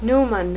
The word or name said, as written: numen